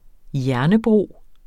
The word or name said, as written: hjernebro